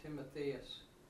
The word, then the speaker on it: Timotheus: Is that how you say it, Timotheus? – Timotheus. – I think you'd say it either way.